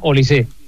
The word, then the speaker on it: Olise: No lleva acento, pero se pronuncia Olise.